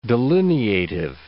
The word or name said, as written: delineative